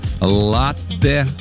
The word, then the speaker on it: латте: И знаете, это правильно, потому что слово итальянское, ударение там стоит на первый слог, латте.